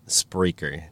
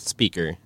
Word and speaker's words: Spreaker: And yes, I'm saying that right, Spreaker, not speaker, but it has an R.